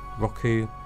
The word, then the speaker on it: Roku: If you have Roku, how's it pronounced? Roku, Roku, R-O-K-U.